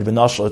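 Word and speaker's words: v'noshal (ונשל): How we read the word is, as we said before, we read it v'noshal. Now, if one would read, as we read the word v'noshal, it's almost, in when you pronounce it, as if you have to add an aleph or a hey between the nun and the shin. V'noshal. So it sounds like there's a hey or an aleph over there.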